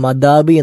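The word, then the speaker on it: അദാബ് (عذاب): നമ്മൾ അദാബ് എന്നൊക്കെ, അല്ലെങ്കിൽ അദാബ് എന്നും അദാബ് എന്നൊക്കെ ആളുകൾ പറയാറുണ്ട്. ശിക്ഷ എന്നാണ് അതിൻ്റെ അർത്ഥം. അദാബ് എന്നതാണ് ശരിയായ ഉച്ചാരണം.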